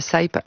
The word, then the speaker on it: Saype: Ça s'écrit Saype. Vous pouvez suivre ça Saïp sur ses réseaux.